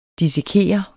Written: dissekere

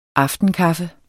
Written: aftenkaffe

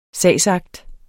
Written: sagsakt

